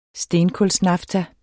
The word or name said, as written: stenkulsnafta